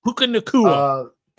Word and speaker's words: Puka Nacua: Pua Nacua, or however you say his name. – Puka Nacua.